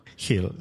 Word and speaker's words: Gil: It's spelled with a G. – G is Gildardo. – But we're pronouncing it with an H. This is a Spanish pronunciation. – Yeah. – So, and your nickname would be Hill? – Hill, yeah. – Just like Gil, but it's Hill.